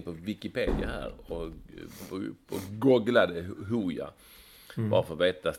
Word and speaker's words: Hooja: Hooja uttalas Hooja är en elektronisk musikgrupp från Gällivare bestående av Joakim Littner och Marcus Martin.